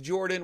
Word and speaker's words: Jordan: My name is Jordan with a silent PH in the middle, and I'm joined always by my wonderful co-host, Sir Lucian, over at Sir Lucian Gaming.